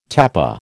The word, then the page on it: tapa: American English